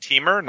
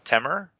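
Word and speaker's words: Teamer: It's pronounced Teemer, not Temer. – It says T-E-A, Mer, rhymes with her, so it's Teemer. – Yeah, it rhymes with lemur.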